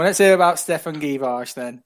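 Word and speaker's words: Djuricic: Am I pronouncing him right? – Givash. – Givash. Oh, I know who Stefan Givash is. – I googled how to pronounce him, and apparently told me to say Givash.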